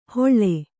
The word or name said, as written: ಹೊಳ್ಳೆ